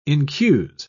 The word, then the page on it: incuse: British English